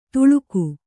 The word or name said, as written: ತುಳುಕು